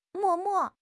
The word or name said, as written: 默默